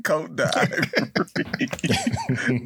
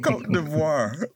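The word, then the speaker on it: Côte d'Ivoire: Stop calling it Cote d'Ivory. Cote d'Ivoire.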